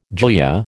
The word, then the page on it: glia: American English